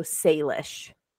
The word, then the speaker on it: Salish: S-A-L-I-S-H. – Yeah, but it's pronounced like say, like S-A-Y. So Salish.